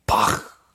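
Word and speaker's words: Bach: 가장 중요한 거는 여러분 발음입니다. 바흐. 앞으로 어디 가서 이제 그냥 바흐라고 하시지 마시고 바흐. 네. 정확한 발음.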